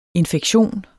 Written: INFEKTIONS

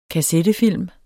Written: kassettefilm

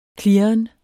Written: klirren

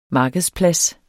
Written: markedsplads

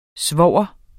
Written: svoger

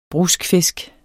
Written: bruskfisk

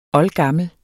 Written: oldgammel

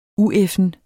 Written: ueffen

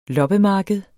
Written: loppemarked

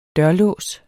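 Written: DØRLÅS

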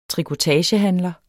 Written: trikotagehandler

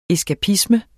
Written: eskapisme

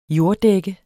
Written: jorddække

